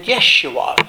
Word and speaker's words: Yeshua: Jesus takes us out of sin and Yeshua. You might say, why did I put it that way? Because that's the Hebrew spelling or pronunciation of Joshua, whose name is also Jesus.